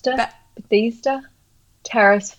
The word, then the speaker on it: Bethesda Terrace: Bethesda Terrace Fountain.